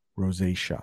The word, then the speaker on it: Rosacea: Just because that is such a hard word to spell, I have the pronunciation on the screen. Rosacea. That's how you say that.